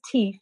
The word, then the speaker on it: Teef: So his name's Teef. It's spelled T-E-E-F.